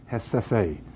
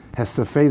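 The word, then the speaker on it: Recife: There's a city there that is written R-E-C-I-F-F-E. If I'm not, if I am correct, it's pronounced Hesefe. Hesefe, they went there because one of their havens for Jews was the Netherlands.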